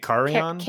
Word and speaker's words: Carrion: It was very close, but how do you say it? Carrion? – I'm saying Carrion. – Carry on? – Like you're carrying on luggage.